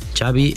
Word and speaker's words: Xavi: Xavi Hernández. – Xavi. Okay. – Yeah, Xavi like chocolate.